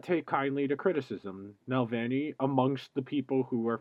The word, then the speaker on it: Navalny: He's more than once voiced support for the leader of the Russian opposition party, Alexei Navalny. Navalny, yes, that's how you say it. I had to look up the phonetic spelling, wanted to be correct.